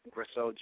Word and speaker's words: Gresseau: C-L-I-F-O-R-D, that's one F in Clifford. My last name is Grisseau, G-R-E-S-S-E-A-U.